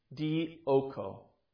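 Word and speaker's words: dioko (διώκω): In the Greek, this is a Greek word, simply pronounced dioko. Dioko.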